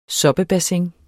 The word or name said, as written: soppebassin